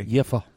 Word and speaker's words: Jever: Uh, Jever, or anybody want to pronounce it in a better way? Jefer. Jefer. Sounds like a curse. V, v is an F yeah, in German.